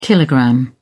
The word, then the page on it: kilogram: British English